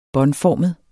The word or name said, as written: båndformet